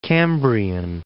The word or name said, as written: Cambrian Mountains